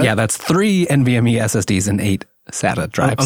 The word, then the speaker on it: NVMe: So the base configuration starts at 16 gigs of RAM, 250 gigabyte NVMe. I said that right this time, right? Didn't I? – Yes.